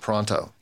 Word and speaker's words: Pronto: Pronto. – Yes. – Oh, pronto. – Pronto, like... Psh. – Oh, I thought it was proto. Oh, yeah, I said it wrong.